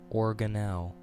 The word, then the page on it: organelle: American English